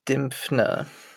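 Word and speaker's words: Dymphna: Saint Dimphia? – Saint Dimphna.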